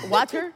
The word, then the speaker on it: water: But what you say in Philly is water. That is how you pronounce it. It's W-O-O-D-E-R, water.